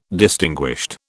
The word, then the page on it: distinguished: British English